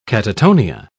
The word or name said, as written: catatonia